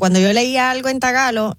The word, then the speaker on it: tagalo: Espérate, hay un lenguaje que se llama trágalo. – Tagalo. – No, tagalo. – Oh. – No se dice filipino, se dice tagalo.